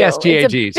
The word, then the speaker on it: PSGAG: And we'll add in the I'm going to mispronounce it, PGSAG. – The PSG. The polysulfated glycosaminoglycans. – Perfect. Thank you. – PSGAGs. Yes. – For the rest of us, yeah. The other one. – Yeah. Just PSGAG.